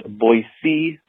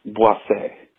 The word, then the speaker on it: Boise: Just to beat the dead horse, I wanted to let you know that it's not pronounced Boise or Boise. It's pronounced Boise, which is also what I call my asshole.